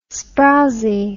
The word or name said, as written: sprowsie